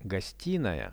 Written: gostinaia

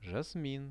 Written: jasmin

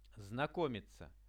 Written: znakomitsia

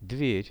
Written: dver

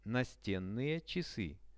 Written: nasten-chasy

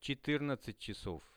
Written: 14-chasov

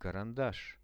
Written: karandash

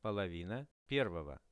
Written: polovina pervogo

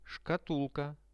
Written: shkatulka

